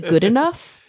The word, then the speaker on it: Goodenough: The 2019 Nobel Prize in Chemistry has been awarded to, I love this guy's name, John Goodenough. – Yeah. Mm-hmm. – Do you actually pronounce it Goodenough? – Yeah.